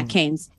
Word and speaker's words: Keynes: Says, yeah, Keynes.